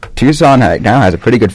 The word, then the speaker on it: Tucson: One of the other writers in All Sports Tucson wrote a special on that when that happened, so I'll take a look real quick.